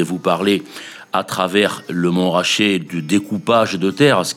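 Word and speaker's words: Montrachet: Écrivez Mont Rachet et prononcez Mont Rachet comme le Mont Chauve, le Mont Pelé.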